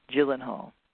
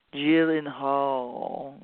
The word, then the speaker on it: Gyllenhaal: Jeff Bridges and Maggie, how do you say her name? Gyllenhaal, there, I said it right. Gyllenhaal, mm-hmm.